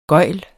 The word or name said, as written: gøgl